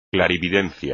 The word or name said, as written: clarividencia